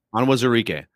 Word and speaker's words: Onwuzurike: I might butcher the pronunciation here, but Onwuzurike, is that how you pronounce it? – Onwuzurike.